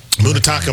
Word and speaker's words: Munetaka: Munetaka Murakami. – That was really good. – Thank you. – Say that, not you, oh. Myron. Say that name again. – Munetaka Murakami.